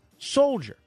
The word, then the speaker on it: soldier: Would, could, should, talk, walk, half, calf, caulk, soldier." Soldier, the L is not silent.